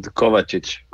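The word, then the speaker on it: Kovačić: Everyone calls you Mateo Kovacic, and I'm not exactly sure that's 100% correct. So how do I pronounce your last name according to you? – You're done good. Kovacic. – Kovacic.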